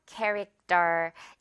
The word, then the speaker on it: character: character 아니고 character. 이렇게 된소리로 쩔 발음 신경 써주면 됩니다.